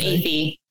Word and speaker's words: Athey: It's Athey.